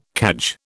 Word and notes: cadge: British English

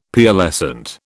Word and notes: Pearlescent: British English

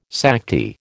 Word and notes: Sakti: American English

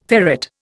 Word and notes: ferret: Indian

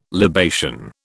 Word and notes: libation: British English

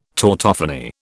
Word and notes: tautophony: British English

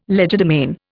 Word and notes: legerdemain: Indian

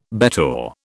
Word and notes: bettor: British English

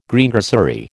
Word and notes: Greengrocery: American English

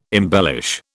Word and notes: embellish: British English

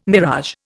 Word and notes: mirage: Indian

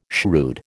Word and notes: shrewd: American English

